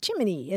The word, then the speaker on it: Gemini: the Federal Space Agency's official pronunciation for its new man in space program, Gemini, is Jiminy, as in Jiminy Cricket.